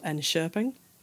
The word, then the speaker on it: Enköping: So, for example, I know that some of the barley comes from a town called Enköping. Which I think is actually how you pronounce that. – Yep. – It's absolutely not how it looks written down.